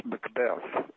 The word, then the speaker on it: Macbeth: It's pronounced Macbeth, as in breath and death.